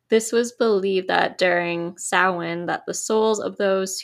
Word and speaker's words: Samhain: and it's spelled Samhain, but it's pronounced Samhain,